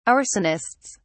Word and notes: arsonists: British English

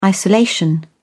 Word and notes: isolation: British English

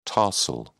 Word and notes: tarsal: British English

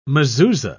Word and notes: mezuzah: British English

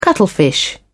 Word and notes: cuttlefish: British English

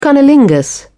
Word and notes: cunnilingus: British English

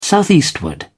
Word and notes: southeastward: British English

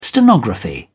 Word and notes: stenography: British English

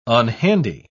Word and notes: unhandy: British English

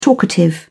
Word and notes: talkative: British English

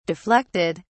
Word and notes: deflected: British English

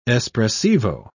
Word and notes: espressivo: British English

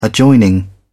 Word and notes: adjoining: British English

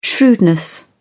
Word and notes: shrewdness: British English

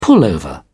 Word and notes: pullover: British English

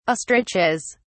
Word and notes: ostriches: British English

